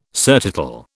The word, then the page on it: surtitle: British English